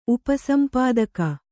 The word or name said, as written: ಉಪಸಂಪಾದಕ